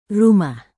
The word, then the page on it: rumah: indonesian